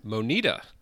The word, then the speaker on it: Moneta: Moneta. – Moneta. How do you spell that? – M-O-N-E-T-A. – Oh, Moneta with an E. I would have gone like M-O-N-I-D-A, Moneta.